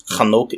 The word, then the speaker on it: Hanok: which is kind of amusing because Hanok is actually the way that you would pronounce Enoch's name in Hebrew.